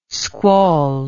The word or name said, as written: squall